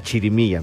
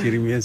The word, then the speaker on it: chirimía: la chirimía, ¿verdad? ¿Sí lo dije bien? – Sí, es chirimía, sí.